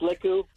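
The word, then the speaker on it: lekku: They told me where to get my Lukus, or however you pronounce that. – I think it's pronounced Leku.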